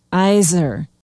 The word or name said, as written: isaar